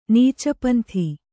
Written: ನೀಚಪಂಥಿ

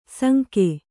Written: ಸಂಖೆ

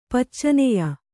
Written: ಪಚ್ಚನೆಯ

